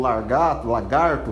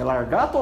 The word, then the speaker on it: lagarto: Aí ele ia lá, colocava um lagarto, não sei como é que se pronuncia o nome, largato ou lagarto? – Lagarto. – Lagarto? Largato não existe.